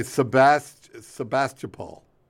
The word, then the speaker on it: Sebastopol: Sebastopol. – Not bad. – You know what? You got me back. – Wait, was I in the ballpark? – Yeah, you're pretty close. Sebastopol.